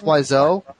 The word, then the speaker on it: Wiseau: in this film, and it's Tommy Wiseau. Is that how you pronounce it? – It's Wiseau. – Wiseau. – So it's W-I-S-E. – Like French.